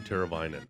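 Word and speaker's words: Teräväinen: Teravainen. What, no, how do you pronounce name? His first name? Teuvo? – Teuvo Teravainen.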